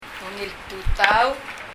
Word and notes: Ungil tutau: ŋil tutau

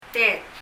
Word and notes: tet: tɛt

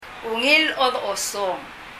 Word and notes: Ungil chodochosong: ŋil ?ɔdɔ?ɔsɔŋ

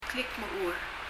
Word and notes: klikmechur: [klikmɛu(:)l]

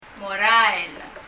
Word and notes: morael: [mɔrɑɛl]

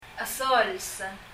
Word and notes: esols: ɛsɔls

